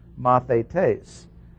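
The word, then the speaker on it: mathetes (μαθητής): The word for disciple is the Greek word mathetes, M-A-T-H-A-T-A-S, pronounced mathetes.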